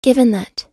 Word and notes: given that: American English

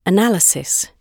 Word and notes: analysis: British English

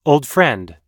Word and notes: old friend: American English

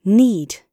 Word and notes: need: British English

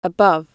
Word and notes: above: British English

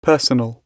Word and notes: personal: British English

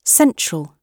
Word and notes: central: British English

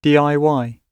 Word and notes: DIY: British English

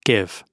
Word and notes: give: British English

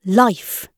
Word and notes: life: British English

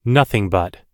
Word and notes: nothing but: American English

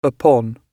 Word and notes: upon: British English

